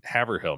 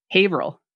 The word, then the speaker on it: Haverhill: So you've also recently become the footwear artist in residence, a term that everybody knows about, at the Buttonwoods Museum in Haverhill, Mass. – Haverhill.